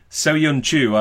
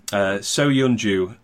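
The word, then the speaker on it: Söyüncü: Soyun Chu, I looked up his pronunciation earlier, seeing as I'm pretty slack on pronunciations. – I'm awful. Absolutely awful. Yeah. – Yeah. Soyun Chu, Leicester's newspaper tells me.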